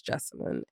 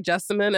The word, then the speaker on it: Jessamyn: I'm like, if you say Lauren the right way, it sounds like Jessamyn.